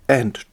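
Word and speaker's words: v: Wenn es nämlich um Gerichtsfälle, die keine Strafprozesse sind, geht es nach dem, wird es ausgesprochen, nicht Smith versus Jones, sondern Smith and Jones. In Strafprozessen dagegen nicht The Crown versus Smith, sondern The Crown against Smith.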